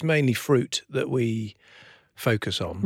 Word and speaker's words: Früt: Of Fruit, which is spelt F-R-U-T.